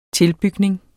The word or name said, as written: tilbygning